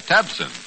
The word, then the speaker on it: Tabcin: So now try Tabsin. That's spelled T-A-B-C-I-N.